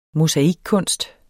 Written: mosaikkunst

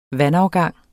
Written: vandafgang

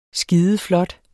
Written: skideflot

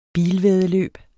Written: bilvæddeløb

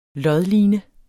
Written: lodline

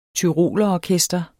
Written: tyrolerorkester

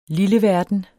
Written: lilleverden

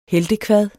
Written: heltekvadet

